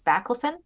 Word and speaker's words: baclofen: But also, they said there's some medicine called, I'm going to get these pronunciation wrong, but baclofen that has had some helping with people with this type of nerve problems. Are you familiar with that medicine? – Yeah, I think you're saying, I think it's baclofen.